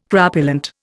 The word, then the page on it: crapulent: Indian